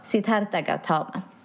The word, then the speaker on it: Siddhartha Gautama: Han hette Siddhartha Gautama. Har du bättre uttal på det? Nej, jag tror, det var, jag tror jag nog är bra. Siddhartha Gautama.